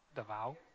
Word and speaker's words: Davao: There's 12 of us going to the Philippines, to Davao City. I was saying it wrong. Davao.